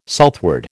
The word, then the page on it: southward: American English